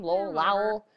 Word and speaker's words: Lowell: Lowell. I'm going like Lowell, Massachusetts. – It works.